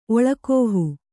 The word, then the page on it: ಒಳ್ಗಾಹು: oḷgāhu